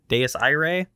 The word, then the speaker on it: Dies Irae: And so we get to see the crew of the, and now we had a whole discussion about how you pronounce Deus Irae. Dies Irae. – That's how they say it on the podcast or on the in the audiobook.